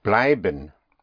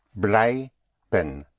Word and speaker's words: bleiben: To stay is bleiben. Bleiben. The sound of I is spelled with an EI in German. So bleiben is spelled B-L-E-I-B-E-N. Bleiben.